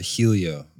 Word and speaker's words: Helio: She held her own, and with only three laps to go, Danica passed up Brazilian Elio Castroneves to take the lead. – Oh, beautifully pronounced. – Ooh. – I would have said Helio.